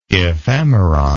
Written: ephemeron

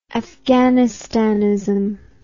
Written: afghanistanism

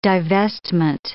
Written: divestment

